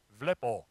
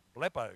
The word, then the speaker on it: blepo: I've got a Greek friend, and I said to him, how do you pronounce that? He goes, vlepo, vlepo. But I like blepo because it starts with B. Blepo.